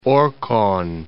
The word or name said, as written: Orkhon